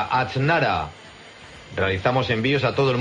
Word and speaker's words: Aznara: Se deletreo aznara por si alguno no lo puede escribir bien o yo lo pronuncio bien, pero Arancha al revés, AXTNARA, Aznara Grupo Tipsa.